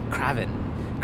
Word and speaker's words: Kraven: Or probably more properly pronounced, Kraven.